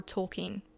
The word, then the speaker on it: Tolkien: Lewis and J.R.R. Tolkien, which I recently learned is the correct pronunciation, I've been calling him Tolkien my whole life, is one of the most significant in history.